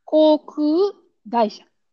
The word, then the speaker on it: 航空会社: だから、うん、 when you pronounce, you just split and pronounce like this. 航空会社。航空会社。 Yeah. And try pre be prepared or like be more aware. Pay attention to the vowel. 航空 like you you have to pronounce equally, right? So 航空会社。 gaisha. Yeah, sounds better.